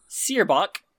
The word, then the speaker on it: Seirboc: Sirbok. – Say his name again, please. – Sirbok. S-E-I-R-B-O-C. Sirbok. Gillrum. G-I-L-L-R-U-M.